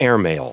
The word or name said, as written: airmail